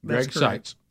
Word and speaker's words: Greg Sykes: And you said it Sites, like I'm looking, like vision. – Yes. – Greg Sykes. – Greg Sykes. Yes.